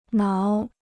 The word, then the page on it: 挠: náo